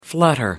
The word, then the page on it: flutter: American English